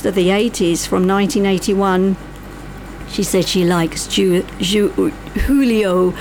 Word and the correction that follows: Julio Iglesias: I should say it properly, Julio Iglesias.